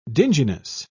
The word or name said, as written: dinginess